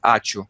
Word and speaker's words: ačiū: But imagine if you didn't know this word, ačiū. A-C-I-U. You, right? It's spelled this way.